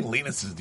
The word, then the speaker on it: Linus: It is Linus. Okay. I just felt like the English accent. – How many Linuses do you know? – I don't know.